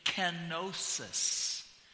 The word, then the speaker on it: kenosis: The transliteration in our English is the pronunciation kenosis.